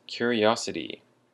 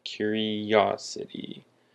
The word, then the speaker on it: curiosity: The first word is curiosity. Curiosity. And the emphasis is on the ah, the curiosity.